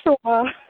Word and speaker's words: Oshawa: How do you pronounce me? the city? Yes. Oshawa.